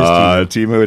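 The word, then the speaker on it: Temu: Timu, am I pronouncing that correctly? – Yes, I believe it is Timu.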